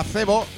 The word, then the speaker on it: Zevo: Cebo, escucha, atiende, escrito con Z y con V, no como cebo de pescar. Si lo quieres encontrar en redes sociales o en internet, se escribe Z-E-V-O, Cebo. Y